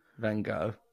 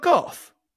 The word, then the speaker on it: Van Gogh: is it not Gough? – Vincent Van Gogh. – I thought the GH would be like cough. – No.